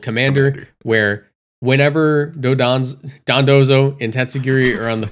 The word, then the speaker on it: Dondozo: Oh, we should explain what Dodonzo is. – Yes, please. – Or Dondozo. Sorry, Dondozo. Michael said it wrong. Michael said it wrong for like a day, and we can't say it right now.